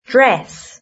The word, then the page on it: dress: British English